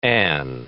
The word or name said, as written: an'